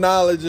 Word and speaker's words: knowledge: You throwing knowledge in there. You're like, you telling, you know what I mean? – The thing about it is the K in knowledge is silent.